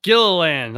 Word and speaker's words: Gilliland: Gilliland, like Disneyland, but Gilliland.